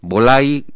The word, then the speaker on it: Wolay: Bolay parece un nombre extraño, pero se escribe con B de Barcelona, W-O-L-A-Y, pero es maya, es el nombre de pantera en maya.